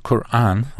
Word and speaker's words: Quran: Well, the Quran, I'll try and pronounce it in the Arabic way. It's not a K, it's a Q sound, and that's a Q at the back of your throat.